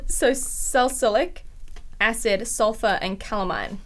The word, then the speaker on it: salicylic: I always pronounce it wrong. It's salicylic. Salicylic. – Salicylic, yeah. Yeah. We said celiac last time, which is...